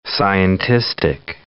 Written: scientistic

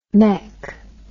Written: mech

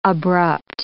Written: abrupt

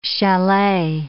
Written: chalet

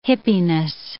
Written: hippiness

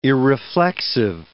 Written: irreflexive

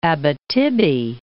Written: Abitibi

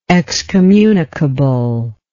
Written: excommunicable

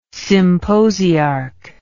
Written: symposiarch

